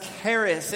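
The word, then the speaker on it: charis (χάρις): This idea of grace that we're talking about today, in the Greek, it's pronounced charis, and this word charis is used to describe an undeserved gift received or given by someone else.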